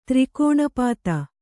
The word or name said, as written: ತ್ರಿಕೋಣಪಾತ